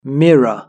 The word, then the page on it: mirror: British English